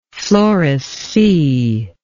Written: floressea

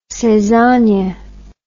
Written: saisonnier

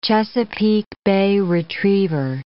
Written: Chesapeake Bay retriever

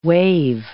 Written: waive

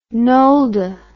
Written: Nolde

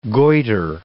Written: goiter

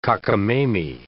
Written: cockamamy

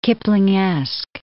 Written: Kiplingesque

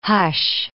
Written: hush

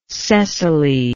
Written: cecily

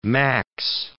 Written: max

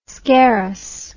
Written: scarus

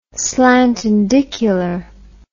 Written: slantindicular

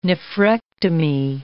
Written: nephrectomy

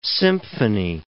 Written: symphony

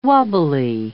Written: wobbly

